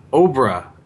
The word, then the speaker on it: Obra: So I like on his site, he goes, Obra, pronounced like Cobra without the C.